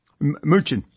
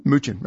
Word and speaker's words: Mnuchin: I can never figure out how to pronounce his name. Is it Stephen M. Mnuchin? – Mnuchin. Yeah. Yes, it is. – Mnuchin? – Mnuchin. That's correct. Yep.